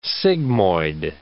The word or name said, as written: sigmoid